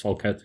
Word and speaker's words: Falcato: I didn't ask you how it's pronounced in Portuguese. – It's Falcato. It's how you said it.